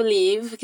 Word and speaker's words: leave: Aqui ela usa o verbo to leave, que significa partir, sair. Perceba que esse verbo tem um som mais longo na primeira sílaba, leave.